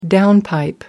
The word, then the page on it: downpipe: American English